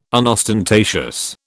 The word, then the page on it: unostentatious: British English